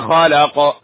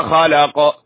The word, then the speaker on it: خلق: والقاف لابد أن تنطق سليما، ليس خلق خلق هكذا كما يقال بعضهم، أو خلأ كما يقال عند بعض الجهات في بعض الدول، يقلبون القاف همزة، هذا خطأ، هذا خطأ، هذا تحريف القرآن.